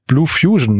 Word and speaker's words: Bloofusion: Der steht nicht für sich alleine, sondern der ist einge, eingefügt äh, in die Blue Fusion. Ich hoffe, ich spreche sie richtig aus, äh, GmbH, ne? Ja. – Genau, richtig ausgesprochen.